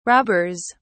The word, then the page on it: robbers: British English